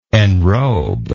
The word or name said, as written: enrober